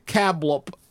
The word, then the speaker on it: Cablp: And it's called, it's spelled C-A-B-L-P. Is Cablp. Cablp. No.